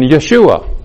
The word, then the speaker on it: Yeshua: But they're talking about what's going on in Jerusalem as far as Jews believing in Yeshua. That's the way they prefer to pronounce Jesus. Yeshua HaMashiach, Jesus the Messiah.